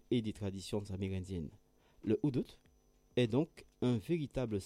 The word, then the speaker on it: Hudut: Le Udut, je vous rappelle que ça s'écrit H-U-D-U-T, est né dans les villages côtiers Garifunas du Belize, principalement d'Andriga, Hopkins, Punta Gorda.